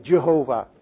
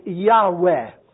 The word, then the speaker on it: Yahweh: So it is a mispronunciation, really, Jehovah. That's too bad for the Jehovah's Witnesses, by the way. And the more academic approach is to say that it is approximately Yahweh.